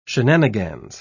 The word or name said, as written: shenanigans